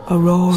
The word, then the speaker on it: Aurora: That's called Half a World Away by, I think you pronounce that, Aurora. – Aurora.